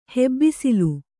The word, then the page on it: ಹೆಬ್ಬಿಸಿಲು: hebbisilu